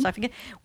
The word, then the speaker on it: sarcophagi: Did I pronounce that right? Sarcophagi? – I think, you did. Sarcophagi, which included an astronaut-looking dude, as like one of the wise men, I guess.